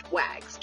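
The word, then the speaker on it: WAGGGS: because it's often pronounced WAGs just like that instead of the full name, which is World Association of Girl Guides and Girl Scouts.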